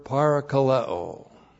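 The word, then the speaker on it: Parakaleo (παρακαλέω): And then we have the verb parakaleo. P-A-R-A-K-A-L-E, long O. Parakaleo.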